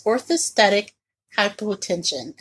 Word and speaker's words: orthostatic hypotension: It's orthostatic hypotension.